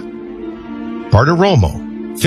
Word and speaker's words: Bartiromo: Birthdays, Ludacris, 49, Harry Connick Jr., 59, and maybe the next White House press secretary, Maria Bartiromo, 59.